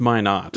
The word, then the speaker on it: Minot: I mean, we had just people, person upon person, just emailing us saying, it's pronounced Minot. – You know, it rhymes with why not.